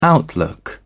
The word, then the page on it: outlook: British English